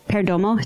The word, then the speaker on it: Perdomo: And then on the 16th, Beehive Cigar is having their Perdomo.